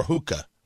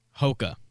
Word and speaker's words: Hoka: Then is the shoe brand pronounced Hoka or Hookah? – Hoka.